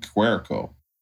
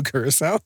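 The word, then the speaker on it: Curaçao: You know, tonight I drank a whole bottle of Blue Cuerco. Is that how you say it? – Curacao.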